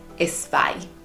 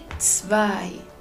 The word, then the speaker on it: zwei: Nosotros hispanohablantes, y te lo digo porque yo lo hago un montón de veces, solemos ponerle la letra E delante cuando es con SSH, con Z, porque, porque esa pronunciación de ts no existe en español. Entonces nosotros queremos ponerle zwei y no, está mal dicho así. Vamos a, intentar hacer el esfuerzo de pronunciarlo bien y vamos a decir zwei, zwei.